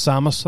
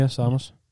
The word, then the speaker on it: Samus: I don't know how it's said. – Yeah, I think you're right. I think it's Samus. I think you're right, yeah. – Samus? – That's what I call her, yeah, Samus.